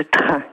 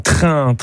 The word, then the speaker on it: Trun: Alors je pensais à la commune de Train, qui s'écrit T-R-U-N. – Ah, Train.